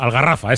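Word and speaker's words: Al-Gharafa: Bueno, pues como decía Dani Guerrero, el Aljafara este, al... – La exigencia. La Gavarra, el Algarrafa. – Algarrafa, ese. Algarrafa, que se equivocó de pronunciación.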